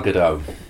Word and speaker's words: Gadot: But before I say that, I thought, how do you pronounce her name? Gal Gadot? – Gal Gadot.